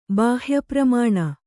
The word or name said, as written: ಬಾಹ್ಯಪ್ರಮಾಣ